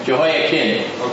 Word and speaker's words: Jehoiachin: Mine says Jehoiachin. Jehoiakim? Chin. Chin. Chin. Well, the CH is pronounced like a K. Jehoiakim.